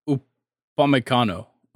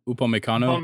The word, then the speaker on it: Upamecano: Umpianaco. – I think it's Upamecano. Upamecano.